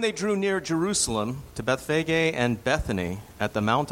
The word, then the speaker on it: Bethphage: Now, before I begin reading, there is a place name here, which, because it's so often mispronounced, when it was imported into the United States, usually the word was changed to Bethpage. So there are cities called Bethpage in America. The name of the city is Bethphage, is how it's pronounced.